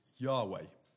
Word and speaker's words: Yahweh: It's pronounced, or they think it's pronounced, and I'll say it quietly because Jews don't like it to be pronounced, but I'll say it. It's Yahweh.